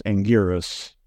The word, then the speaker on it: Anguirus: Anguirus, the way it's spelled, the official spelling is Anguirus. U-I-R-U-S, but it's pronounced Anguirus. So we'd have to come up with a much more phonetic spelling of it. I think if we do G-W-E-E-R-S.